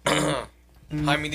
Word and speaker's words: Jaime: Jaime De Niro.